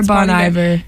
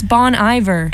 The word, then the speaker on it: Bon Iver: Bon Iver or Bon Iver? – No, I think it's Bon Iver because I'm pretty sure that it's like Good Winter.